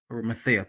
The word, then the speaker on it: Arimathea: And this is why, before the sun went down, Joseph of Arimathea, Arimathea, probably saying that wrong. Um, Arimathea. Arimathea, thank you.